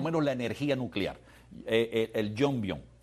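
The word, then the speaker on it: Yongbyon: ¿Es así se pronuncia? Jongbyon, ajá. – Exacto.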